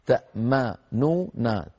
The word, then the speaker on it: تأمننا: تأمن فعل مضارع آخره نون مضمومة وقبله ميم مفتوحة، تأمننا، تأمننا، فالتقى في النطق ثلاث حروف غنة: الميم والنون والنون الثانية، ميم ونون ونون ثانية، ثلاث أحرف غنة متتالية، تأمننا، ففيها شيء من الثقل، جربوها هكذا، تأمننا، تأمننا، ثقيلة، فلذلك خففت بتسكين الحرف الأوسط.